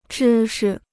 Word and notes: 知识: zhī shi

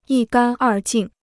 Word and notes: 一干二净: yī gān èr jìng

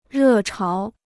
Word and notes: 热潮: rè cháo

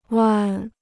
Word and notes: 弯: wān

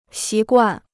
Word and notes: 习惯: xí guàn

